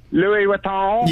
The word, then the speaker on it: Louis Vuitton: Louis Vuitton? – Yeah.